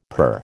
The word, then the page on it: purr: American English